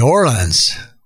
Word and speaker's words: The Orlons: And I'm, I'm, predi- I'm thinking that's how to say their name. O R L O N S.